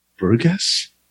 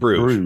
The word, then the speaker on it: Brugge: Bruges, right? Bruges, yeah, like Bruges. In Bruges, the movie in Bruges. – Yeah. – No, Brugge. – Brugge. – That's how you pronounce it. – Brugge. – Brugge. It's like you're halfway through the word burger and throw it in reverse.